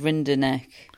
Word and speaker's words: Rinderknecht: Also on court number three, Oscar Otter and Arthur Rinderknecht. Not confident about that pronunciation. Matt's going as Rinderknecht. – I think you did all right then.